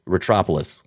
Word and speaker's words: Retropolis: Don't try not to put the rat too hard in there. It's just Retropolis. – Like Metropolis, yeah.